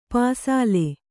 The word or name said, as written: ಪಸಲು